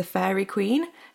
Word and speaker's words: Faerie Queene: The poem overarching title is The Faerie Queene, spelled like F A E R I E Q U E E N E. Like the fairy queen.